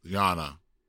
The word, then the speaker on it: Jonna: Jonna, pronounced Yona like Donna. Oops. Jonna, pronounced Yana like Donna. Like Donna. Yana. Okay. – Oh, Yana. Because the J is like a Y sound.